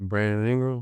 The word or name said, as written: Brandon Ingram